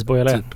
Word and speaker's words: Beaujolais: Beaujolais. Precis, Beaujolais.